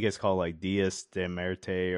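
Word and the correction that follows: Día de los Muertos: or something like that. I said that wrong for sure. – Oh, Dia de los Muertos?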